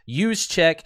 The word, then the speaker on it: Juszczyk: Now the way you pronounce his last name is use, the Northeast people, right? Or Midwest people, and Juszczyk. So it's a soft J, yogging, I believe is what the kids are calling it nowadays. But Juice, he goes by Juice, Juszczyk.